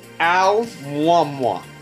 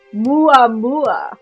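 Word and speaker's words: Muamua (ʻOumuamua): Amwamwa. All the U's are replaced with W's. – Okay, so you're so close. It's actually pronounced O, O-H, Muamua. With the Muamua.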